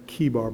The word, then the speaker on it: Chebar: Chebar, that the heavens were opened, and I saw visions of God. I think it's Chebar, by the way. It's hard C-H there.